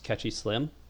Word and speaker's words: Catchy Slim: My name is Catchy Slim, and that's spelled just like it sounds.